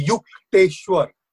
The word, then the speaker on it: Yukteshwar: The correct pronunciation will be Yukteshwar.